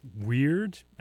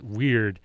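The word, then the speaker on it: Wyrd: Following that up was Gauls Weird, I think is how you pronounce it. It's W-Y-R-D. I think it's, I look at it and I look at like, if I have any real nerds in the audience, you might know the name for a dragon that has no wings is a Wyrm.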